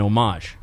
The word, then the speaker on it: homage: Generally, that's considered as an homage, mm-hmm. and that's spelled H O M A G E, silent H.